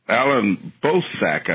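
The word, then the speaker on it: Allan Boesak: Alan Bozaik, I believe is how you pronounce it, is a well-known advocate in South Africa against apartheid.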